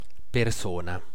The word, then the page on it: figure: /ˈfɪɡ.jɚ/; [ˈfɪɡ.jɚ]; /ˈfɪɡ.ə/